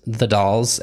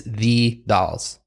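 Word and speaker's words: The Dalles: Pretty sure it's pronounced the Dolls, and she made it a point in the blog. It is the Dolls. – Not Dallas. Sure why. – Not Dallas, not Dolls, Dolls, the Dolls, Oregon.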